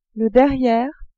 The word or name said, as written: derriere